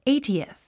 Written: eightieth

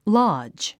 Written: lodge

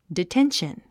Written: detention

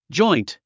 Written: joint

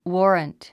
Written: warrant

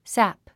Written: sap

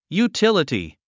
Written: utility

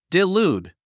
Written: delude

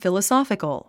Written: philosophical

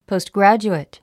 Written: postgraduate

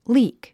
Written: leak

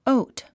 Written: oat

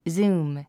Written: zoom

